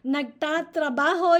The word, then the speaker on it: nagtatrabaho: Nagtatrabaho, you notice the prefix nagta. And then trabajo repeats the first syllable. Tatrabaho. Tra-trabaho, nagtatrabaho. It means present tense.